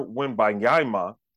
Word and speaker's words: Wembanyama: Wembanyama. Wembanyama. Let me say, the man going to be the number one overall pick. I better get his name right. – Yeah, Victor Wembanyama.